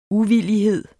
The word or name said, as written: uvillighed